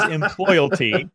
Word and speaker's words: employalty: Well, first of all, you already get 10 bonus points for pronouncing it correctly. It is employalty.